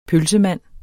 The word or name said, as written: pølsemand